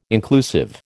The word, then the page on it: inclusive: American English